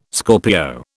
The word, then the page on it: Scorpio: British English